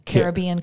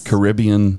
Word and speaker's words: Caribbean: So, Caribbean Queen. Mm-hmm, yes. Yeah. – So there are two major ways to pronounce it. – Yes. – Caribbean. – Yes. – And Caribbean.